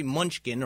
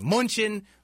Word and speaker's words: Mnuchin: But Secretary Treasury Mnuchin, or whatever he calls himself, how do you say his name? Mnuchin, anyway. Anyway, that's his, it spells it M-N-U-C-H-I-N, Mnuchin, or whatever.